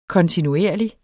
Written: kontinuerlige

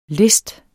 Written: list

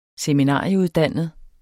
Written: seminarieuddannet

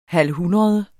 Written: halvhundrede

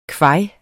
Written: kvaj

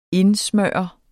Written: indsmøre

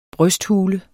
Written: brysthule

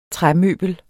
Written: træmøbel

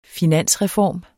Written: finansreform

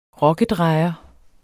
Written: rokkedrejer

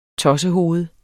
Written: tossehoved

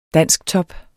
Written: dansktop

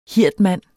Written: hirdmand

